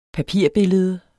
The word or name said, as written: papirbillede